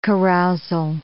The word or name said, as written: carousal